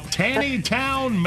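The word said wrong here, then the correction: Taneytown: Maryland? Is that you? Where you are, Teresa? – You know, Jackie is sitting right behind you. You could just ask. – Yeah. It's pronounced Tawny Town. Tawny Town.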